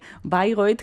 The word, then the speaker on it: Bayreuth: Bueno, no me sorprende, no me sorprende Bayreuth, que lo pronunciamos a la alemana, pero que se escribe para los oyentes que no lo sepan, Bayreuth, está en Alemania.